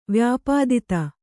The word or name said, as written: ವ್ಯಪೇತತೆ